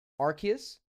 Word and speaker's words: Arceus: We'll talk about Arceus. Right? That's how it's pronounced? – Yeah. That's what I'm hearing. – Yeah, I keep looking at it and wanting to say Arceus, but I guess RCE like arc.